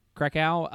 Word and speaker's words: Krakow: So in Krakow, is that how you pronounce it? – Krakow.